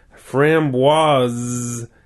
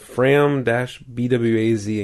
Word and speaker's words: framboise: Pronounced as framboise. They have fram-BWAZ in capital letters for the stress on the pronunciation. With the stress on the second syllable.